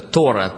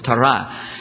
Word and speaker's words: Тора: Русские называют ее Тора. По-еврейски правильное ударение на последний слог – Тора.